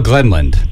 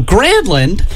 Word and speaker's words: Granlund: Let's talk about Mikael Glenland. And that is his name, and that's how we say his name. It's Mikael Glenland, not Michael Grandland, as Willie Donick likes to say.